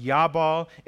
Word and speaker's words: Jabal: In Hebrew, they're pronounced Yabal and Yubal.